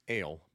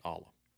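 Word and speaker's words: Alle: It is not the Ale, but the Ala.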